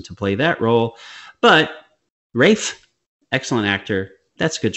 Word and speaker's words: Ralph: It's got some great, I mean, Rafe, why is it called pronounced Rafe when it's spelled Ralph? – Because anyway, they're funny. Because they're funny out there, because they don't like to say Ralph.